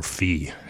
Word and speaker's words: phi: It's also the Greek for that is phi, or phi is the way you pronounce it, and the Greek letter.